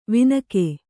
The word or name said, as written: ವಿನಕೆ